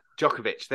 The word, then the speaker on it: Djokovic: Novak Djokovic, who was in the tennis player? – The tennis player. – Novak Djokovic. – Djokovic,